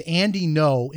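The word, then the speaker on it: Andy Ngo: because his name is Andy No, and it's spelled N-G-O, but it's pronounced Andy No. So if you want to write it down, the last name is spelled N-G-O.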